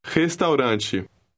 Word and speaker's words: restaurante: Restaurante. – Notice that the R at the beginning of the word sounds like a hard H.